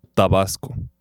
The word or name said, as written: Tabasco